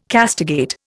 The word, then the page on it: castigate: Indian